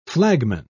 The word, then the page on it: flagman: British English